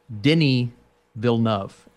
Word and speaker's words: Denis Villeneuve: Moving on here, I want to say that with the new Dune from director Denis Villeneuve, help me out here. – Dude, listen to this. It's Denis Villeneuve, and it took me 2 years to figure that out.